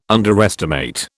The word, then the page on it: underestimate: British English